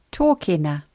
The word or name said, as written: tokena